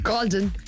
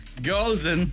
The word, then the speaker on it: Goulding: The one and only Ellie Goulding, or Goulding, or whoever you say. How would you say her name? – Golden. – Golden.